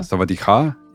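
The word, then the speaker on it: савадиха (สวัสดีค่ะ): Например, там, на тайском отдельно говорят женщинам привет и мужчинам по- по-разному. – Интересно. – Да, савадиха и савадихап.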